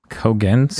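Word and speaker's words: Cogen: Kogen? Spell that? – It's C-O-G-E-N.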